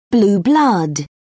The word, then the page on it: blue-blood: British English